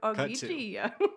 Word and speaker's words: Ogygia: He's chilling over on the island Ogygia with the goddess Calypso. Okay. – Is that how they talk? – Yeah. – Okay. – Cut to the island of Ogygia.